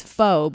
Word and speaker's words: pho: So please tell me, if people pronounce it pho, it looks P-H-O. People always think it's pho, but it's pronounced pho.